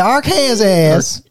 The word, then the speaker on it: Arkansas: It's me, DM Rockfist, coming down from Arkansas. – Is that how you pronounce that? – Yeah, Arkansas.